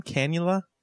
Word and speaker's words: cannula: If we see the how do you pronounce it again, cannula? – I think that's so awkward.